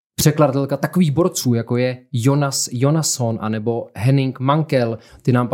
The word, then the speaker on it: Jonas Jonasson: Jonas Jonasson se vyslovuje Jonas Jonasson.